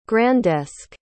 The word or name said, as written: grandesque